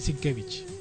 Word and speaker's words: Sienkiewicz: Ver, en realidad se dice Sienkiewicz.